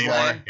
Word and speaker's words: Namor: Namor's in it, who I also think is Namor.